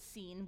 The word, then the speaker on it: Sin: and that doesn't mean sin, that was a Hebrew word, they'd pronounce it seen, we just say sin.